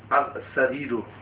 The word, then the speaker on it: السرير: السرير. – These are حروف قمرية. شمسية, sorry. You don't pronounce that lam.